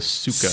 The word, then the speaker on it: Psyche: Although they call it the Suka. – Suka. – Which apparently is based on the original Greek pronunciation. – Oh. – Because the Y in Greek is upsilon.